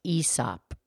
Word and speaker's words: Aesop: Actually, I think his name is pronounced Aesop.